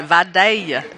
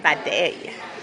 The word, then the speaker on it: Vidalia: You got to say it right. Vidalia. – Vidalia.